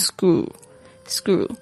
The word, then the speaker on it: screw: 那这边呢我们的用法是动词，就是旋紧这个动作就是 screw。大家特别注意一下这个发音，这个 C 呢在 S 后面发个的声音 screw, 然后你不要忘记那个 R 的声音有点难念哦，你的舌头要摆对位置它会把它念好 screw.